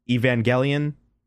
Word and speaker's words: Evangelion: I've been calling it Evangeline. Apparently it's called, like, Evangelion.